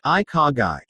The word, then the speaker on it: Ikigai: Ikigai, pronounced I-ka-gai, is, above all else, a lifestyle that strives to balance the spiritual with the practical.